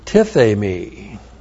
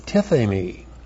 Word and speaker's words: tithemi: Now it is followed by the aorist active infinitive of the verb tithemi. T-I-T-H long E-M-I. Tithemi. Tithemi means to lay down.